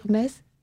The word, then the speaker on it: Metz: Tu avais envie de modifier le regard porté sur cette ville que bon nombre, excepté les Lorrains, prononcent très mal. Ils n'arrivent pas à dire Metz.